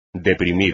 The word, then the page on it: deprimir: espagnol